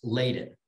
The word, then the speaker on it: Ladin: And not that your name isn't hard to pronounce, but just so you know, it's pronounced Ladin.